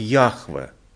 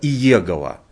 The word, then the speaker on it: Яхве: И все трепещали перед этим именем сущий, который по-еврейски звучало как Яхве. А многие наши радиослушатели, может, слышали другое произношение этого имени, как Иегова. Вот Иегова – это неправильное прочтение этого древнееврейского слова «сущий», правильно «Яхве».